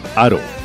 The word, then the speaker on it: Haro: Se escribe con H. Haro.